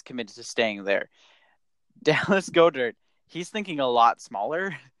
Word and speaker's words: Goedert: Your tight end that you guys drafted, Dallas, what is it, Goedert, actually. – Goedert, okay. – Goedert, yes, the T is pronounced.